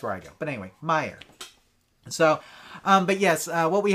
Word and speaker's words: Meijer: Oh, it's pronounced Meijer. Meijer.